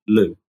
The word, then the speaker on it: Looe: There's one that's for the main line and one is for the branch line down to the little fishing village of Loo. – Yes, and that's spelled L-O-O-E, not L-O-O.